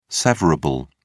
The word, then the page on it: severable: British English